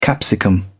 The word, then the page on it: capsicum: British English